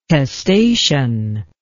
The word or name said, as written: testacean